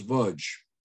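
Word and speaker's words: Łódź: This was the gates of a ghetto, the Ludge ghetto, pronounced Vudge.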